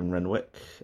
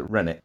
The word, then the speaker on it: Renwick: So the show written by David Renwick. There's some people like to pronounce it Rennick, but it is Renwick, because hearing the great man himself pronounce it as Renwick is good enough for me.